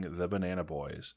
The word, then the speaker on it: Banana Boyes: You can find them on Facebook, Twitter, and on Instagram by searching The Banana Boys. And as always, it's spelled B-O-Y-E-S like an oh yes.